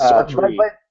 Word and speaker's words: Sartre: It's Sartre.